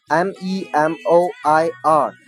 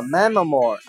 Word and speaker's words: memoir: If I die 好，继续往下，memoir M E M O I R。那么这个单词呢，不读作 memoir 啊，有同学看着这个词形就照着往下读啊。那么这个单词的发音有点怪，叫 memoir，其实它来自于法语单词，它的意思是名词自传、回忆录。